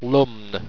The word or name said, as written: lumn